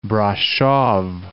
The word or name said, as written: Brasov